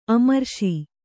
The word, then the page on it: ಅಮರೇಶ: amarēśa